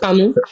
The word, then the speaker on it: Camus: It's Camus.